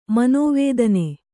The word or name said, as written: ಮನೋವೇದನೆ